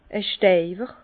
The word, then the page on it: e Steiwer: Bas-Rhin; Herrlisheim